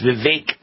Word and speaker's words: Vivek: And that's how he says it's pronounced. Vivek rhymes with cake.